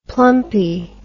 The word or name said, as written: plumpy